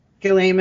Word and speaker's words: Ghislaine: Ghislaine Maxwell. – Ghislaine.